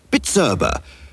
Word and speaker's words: Bizerba: The manufacturer of the finest high-tech weighing, slicing and price labelling machinery is Bitzerba, B-I-Z-E-R-B-A, pronounced Bitzerba. So now you can pronounce it, give your business the Bitzerba boost today.